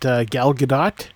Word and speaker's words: Gal Gadot: And I thought that Gal Gadot, although it's hard to say, apparently that's how she pronounces her name.